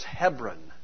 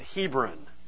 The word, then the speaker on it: Hebron: And to them that were in, the way it's pronounced in the Bible is Hebron. Okay, here we pronounce it Hebron.